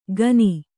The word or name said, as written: ಖನಿ